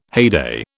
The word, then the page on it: heyday: British English